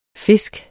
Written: fisk

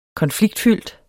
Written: konfliktfyldt

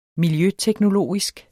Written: miljøteknologisk

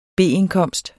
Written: B-indkomst

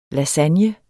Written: lasagne

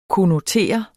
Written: konnotere

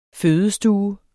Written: fødestue

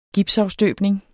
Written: gipsafstøbning

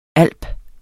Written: alp

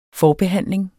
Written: FORBEHANDLING

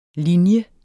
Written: linje

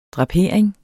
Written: drapering

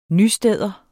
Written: nysteder

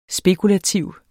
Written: spekulative

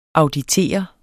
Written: auditere

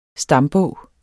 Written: STAMBOG

